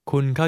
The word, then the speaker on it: คุณ: คุณเข้าใจครับคุณ The Thai word you is pronounced with a mid-level tone of voice, neither high nor low.